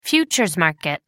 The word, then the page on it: futures market: American English